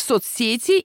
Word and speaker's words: соцсеть: В соцсети или в соцсети? В соцсетях или соцсетях? Ударение в этом слове ставим там же, что и в слове «сеть» во всех значениях. В единственном числе ударным остается корень, нет соцсети, родительный падеж, обратился к соцсети, дательный падеж, удивлен соцсетью, творительный падеж.